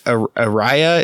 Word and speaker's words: Araya: One of the band members, Araya, is how his name's pronounced.